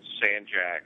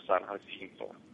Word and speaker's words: San Jacinto: For the uneducated, if you're a Houston native, it's San Jack or San Jacinto. Kind of like over in Austin, there's a street, Manchac. But the actual proper pronunciation is San Jacinto.